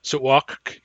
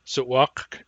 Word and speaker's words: Sutwak: Sutwak, is that you? Sutwak consists of two syllables. Sutwak. The K at the end of the word is released, but it has no vowel next to it.